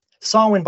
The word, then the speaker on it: Samhain: Samhain, by the way, if you're listening to this, isn't spelt how it sounds.